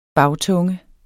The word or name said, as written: bagtunge